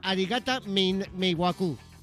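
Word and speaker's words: Arigata Meiwaku (ありがた迷惑): ¿Arigata? Sí, ¿Arigata? No. – Arigata Meiwaku. – Meiwaku.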